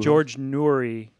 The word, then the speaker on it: George Noory: George Noory, I'm pretty sure is how you say his name.